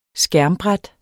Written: skærmbræt

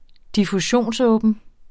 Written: diffusionsåben

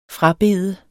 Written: frabede

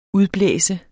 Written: udblæse

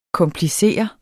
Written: KOMPLICERES

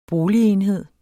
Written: boligenhed

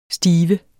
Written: stiver

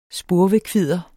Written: spurvekvidder

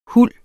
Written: huld

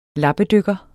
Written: lappedykker

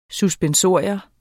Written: suspensorium

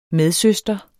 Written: medsøster

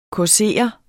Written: causere

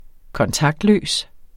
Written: kontaktløs

